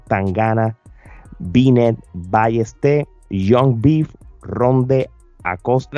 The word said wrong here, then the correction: Randy Acosta: Randy Acosta, como le dicen.